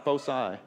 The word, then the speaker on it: foci: How do you say that? Foci or foci? Foci? Foci? F-O-C-I? Latin? – Foci.